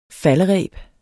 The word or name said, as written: faldereb